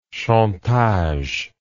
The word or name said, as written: chantage